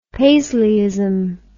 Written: paisleyism

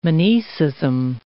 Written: monoecism

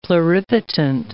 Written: pluripotent

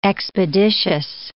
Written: expeditious

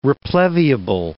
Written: repleviable

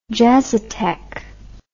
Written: jazzotheque

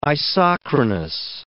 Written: isochronous

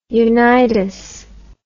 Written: unitas

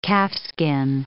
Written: calfskin

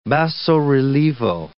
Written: basso-rilievo

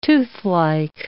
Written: toothlike

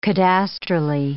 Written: cadastrally

